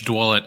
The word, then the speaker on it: Dwulet: Yeah, it's Dwulet. I get about a lot. I get about 5 to 6 different pronunciations, so I appreciate okay.